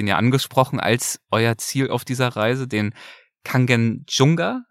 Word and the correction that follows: Kangchenjunga: spricht das richtig aus? Kanchenjunga. Kanchenjunga, okay.